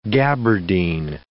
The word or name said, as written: gabardine